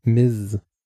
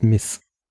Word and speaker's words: Ms: benutzt man dieses MS und es spricht sich, so wie ich es in Erinnerung hatte, mit einem stimmhaften S am Ende, also Miss. Also nicht zu verwechseln mit Miss. Oh Gott. Also mit einem stimmlosen S.